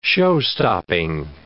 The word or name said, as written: showstopping